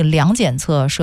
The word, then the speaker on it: 量检测: 好，我先更正一个读音啊，是这个光精密量检测设备哈，量检测装备，量测和检测。